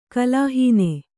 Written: ಕಲಾಹೀನೆ

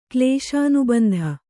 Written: ಕ್ಲೇಶಾನುಬಂಧ

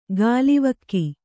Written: ಗಾಲಿವಕ್ಕಿ

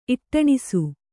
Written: ಇಟ್ಟಣಿಸು

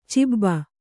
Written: ಚಬ್ಬೆ

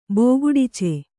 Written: ಬೋಗುಡಿಚೆ

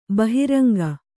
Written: ಬಹಿರಂಗ

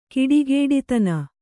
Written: ಕೆಡುಗುಡಿತನ

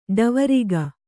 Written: ಡಾವರಿಗ

